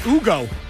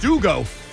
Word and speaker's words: Hugo: That's pronounced Ugo. H-U-G-O is pronounced Ugo.